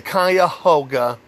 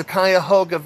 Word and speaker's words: Cuyahoga: Number 13, I learned how to pronounce this thanks to the river that was sung about at the beginning of the movie Major League. The Cuyahoga River is what they're talking about.